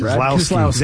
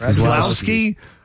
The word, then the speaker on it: Keselowski: Keselowski. – That's how you say it? – Keselowski.